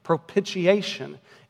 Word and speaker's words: propitiation: Propitiation.